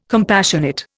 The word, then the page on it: compassionate: Indian